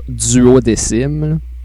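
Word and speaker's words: Duodecim: Là, Squeenix, ils sortent un jeu, genre, c'est 12, mais ils veulent qu'on prononce ça duodécim.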